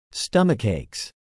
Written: stomachaches

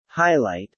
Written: highlight

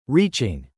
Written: reaching

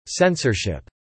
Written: censorship

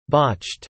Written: botched

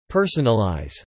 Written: personalize

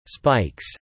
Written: spikes

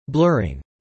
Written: blurring

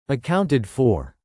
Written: accounted for

